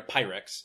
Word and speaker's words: Pyrrhic: I don't quite know how to pronounce it because there's a P and an H and a Y and a couple of R's in a weird place in that. – Tim says later, a Pyrrhic.